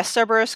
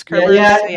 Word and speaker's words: Cerberus: Cerberus, Kerberus, yeah.